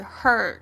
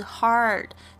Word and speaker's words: heart: 这个 heart 代表要大家要特别注意一下这个的发音，因为它不是 hurt，不是 hurt，不是不是那个受伤那个声音，而是 hard。